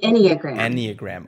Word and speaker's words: Enneagram: And if I'm pronouncing that right, it's called Enneagram? – Enneagram.